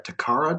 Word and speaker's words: Takara: But so Gridman, from what I can recall and what I've researched, was a joint thing by Super Eye and Takara. I think I'm pronouncing the name right. Takara Toys? – Yeah.